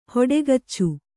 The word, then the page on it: ಹೊಟ್ಟೆಗಿಚ್ಚು: hoṭṭegiccu